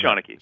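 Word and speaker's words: seanchaí: I would not even know how to pronounce that until you said it's Shaunaki.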